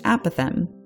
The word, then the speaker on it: apophthegm: Even with the extra P-H, it's still pronounced apothem, not apophthegm, because the PH, like the G before the final M, is silent.